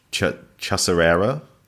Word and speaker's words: Chacarera: And is it, how to pronounce, is it Chacarera. – Chacarera.